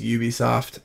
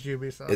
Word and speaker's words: Ubisoft: Ubisoft or Ubisoft, however you guys want to pronounce it. I think it's Ubisoft. – It is Ubisoft.